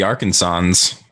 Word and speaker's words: Arkansans: The Fancher Party was a wagon train of upper middle class Arkansans. I can never say that right. I think you had it right. The Arkansans.